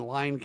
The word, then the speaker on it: Lyon: L-Y-O-N. – Lyon, it's not Lynn. – Nope. Well, this is Lynn, the city of Lynn. – All right, but that's Lyon County. – Mm-hmm. – Lynn in Lyon County.